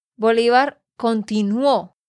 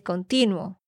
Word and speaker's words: continuó: Tienes que decir, Bolívar continuó Continuó. – Porque tú dijiste continuo.